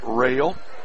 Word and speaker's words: Roell: One of two free throws by Rail of Twin Valley South, spelled R-O-E-L-L, pronounced Rail, 6'4 junior, one of two at the stripe.